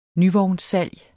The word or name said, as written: nyvognssalg